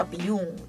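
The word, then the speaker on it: Biung: 原住民的名字我知道怎么念，叫 Biyung，因为我刚刚觉得不确定，所以我就没有念出来。那他在两千年的时候，他发行了《猎人》的专辑。在两千零一年，我那时候一岁，他发行了王洪恩 Biyung 的专辑。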